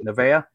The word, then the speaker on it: Neveah: Nevea, is that right? Am I saying it right? – Neve. – Neve. See, that's what he said, and I'm like, no, it says Nevea.